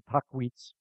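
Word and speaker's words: Tahquitz: Some pronounce it Tockwitz Canyon. It's an Indian word.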